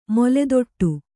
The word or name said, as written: ಮೊಲೆದೊಟ್ಟು